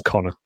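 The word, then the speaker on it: Conchúr: It's spelled Conchur. I didn't want to mess it up. He had actually three other names in his profile after that. And it's Connor. Is that how you pronounce it? The Irish version of Connor, but I didn't want to butcher it.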